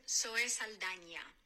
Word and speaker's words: Zoe Saldana: So are you Zoe Saldana? – I'm Zoe Saldana.